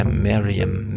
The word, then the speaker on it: Meryem: Und Miriam, so wird sie, glaube ich, ausgesprochen. – Miriam? Miriam? – M-E-R-Y-E-M, Miriam, Miriam und hat auch geschickt, sehr gut unterstützt diesen Podcast.